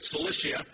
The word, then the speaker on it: Cilicia: Cilicia.